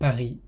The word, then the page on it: Paris: French